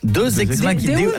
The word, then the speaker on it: deus ex machina: Deus ex machina. – Parce que deux, j'aurais dit que c'était deux ex que t'appelles machine. – Non, ça se prononce deus ex machina.